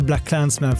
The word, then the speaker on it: BlacKkKlansman: Eh, Ice Cube gjorde en skiva 1990 som hette America's Most Wanted där han stavar med 3K och då uttalas det bara America, Så att vi säger Black Klansman för liksom konsonantenkelhetens skull. Vi går på det uttalet i den här podden.